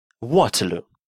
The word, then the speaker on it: Waterloo: Waterloo, поселение в 15 километрах от Брюсселя под названием Waterloo, произносится на английский манер.